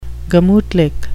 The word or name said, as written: gemutlich